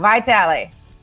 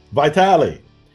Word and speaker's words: Vitale: Say it right, because everybody says it's wrong. Vitale. – Vitale. Unless you're in Italy. Vitale.